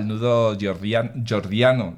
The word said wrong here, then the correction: Gordiano: ¿lo he dicho bien? Jordiano. – Gordiano.